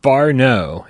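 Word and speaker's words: whānau: He says, Far No is the phonetic way to say the Te Rio Maori word for family. Wa No, pronounced Far No.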